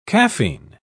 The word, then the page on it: caffein: British English